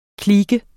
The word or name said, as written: klike